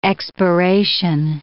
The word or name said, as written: expiration